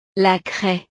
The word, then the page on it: la craie: frances